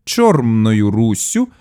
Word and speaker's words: Чормною Руссю: У той час, коли частина Заходу України називалася не Червоною Руссю, як це зазвичай вимовляється сьогодні, а за давньоукраїнським зразком Чормною Руссю з м'якою вимовою першого О.